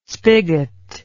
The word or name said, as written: spigot